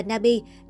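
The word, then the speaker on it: Nabi (나비): Điều đáng nói trong tiếng Hàn, bướm được phát âm là Nabi, trùng tên với tài khoản Instagram của Âu Dương Nana.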